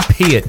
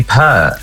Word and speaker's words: Peart: Lee Peart, to rhyme with ear. Yes? – Oh, yes. Well, some people say Pert.